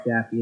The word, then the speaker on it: Jaffe: So, David Jaffe, and I think it's pronounced Jaffe. It's J-A-F-F-E.